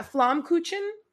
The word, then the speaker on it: Flammkuchen: You have Flammkuchen. I'm probably mispronouncing this. I'm so sorry. That is sounds right. – Yeah, Flammkuchen. Yeah, I'm trying.